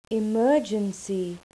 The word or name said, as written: emergency